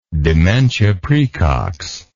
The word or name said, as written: dementia praecox